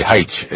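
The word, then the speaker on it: Haich: I studied with, actually, Heitsch is how they say her name.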